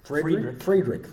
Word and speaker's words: Friedrich: And it's based on this German nobleman, Hieronymus Karl Friedrich. – Friedrich, thank you. Put the long I on it. I'm from Oklahoma.